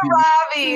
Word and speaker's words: Margot Robbie: Margot Robbie is how you say it.